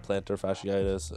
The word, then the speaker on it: plantar fasciitis: and that's why he got that plantar fasciitis. I think that's how it's pronounced. – Pretty sure, yeah, I think you got it right.